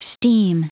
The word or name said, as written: steam